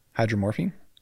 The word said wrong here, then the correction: hydromorphone: Because I thought it was pronounced hydromorphine, but it was saying it was hydromorphone, like phone at the end.